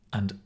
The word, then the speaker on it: and: The firewall, of course, will protect you from viruses, malware, and such things. Binomials. So a binomial is where we put two nouns together, usually separated with the word and. And we pronounce and as an.